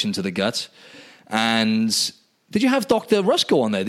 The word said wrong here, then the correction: Ruscio: Yeah, Ruscio. – Ruscio. Yeah, that's how you pronounce it.